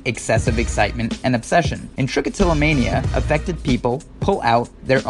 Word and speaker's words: trichotillomania: This week's Doctionary word is trichotillomania. Trichotillomania has 7 syllables, 16 letters, and it's got nothing to do with dinosaurs.